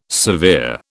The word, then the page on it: severe: British English